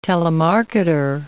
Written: telemarketer

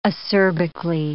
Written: acerbically

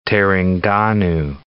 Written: Terengganu

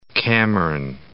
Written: Cameron

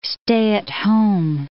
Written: stay-at-home

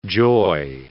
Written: joy

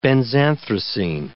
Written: benzanthracene

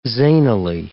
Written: zanily

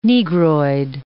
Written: Negroid

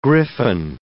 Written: griffon

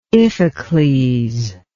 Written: Iphicles

